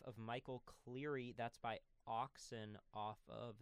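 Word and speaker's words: ØXN: And just because, in order to help, because you will never be able to spell these right on your own, that's O-X-N, pronounced Oxen, and then Sirum is C-Y-R-M.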